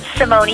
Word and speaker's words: Simone: Simone. It's actually the Italian pronunciation.